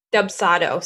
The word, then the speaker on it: Dubsado: D-U-B or? – Dubsado. So it's spelled D-U-B-S-A-D-O.